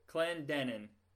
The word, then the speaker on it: Clendenin: This episode, we'll be interviewing actor Bob Clendenin. I say it wrong every time, don't I? – It's okay. It's the second syllable. – Clendenin. Did I get it right? – Yeah, it's perfect.